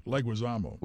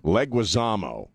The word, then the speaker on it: Leguizamo: How do you say the guy's last name? – Leguizamo. – Leguizamo.